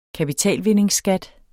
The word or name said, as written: kapitalvindingsskat